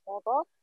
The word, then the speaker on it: Hodo: My name is actually Hodo, it's pronounced Hodo, and it is Somali for enrichment.